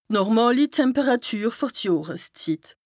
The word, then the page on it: d' normàli Temperàtüre fer d' Johreszit: Bas-Rhin; Strasbourg